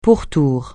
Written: pourtour

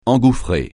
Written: engouffrer

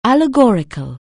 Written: allegoric(al)

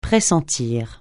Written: pressentir

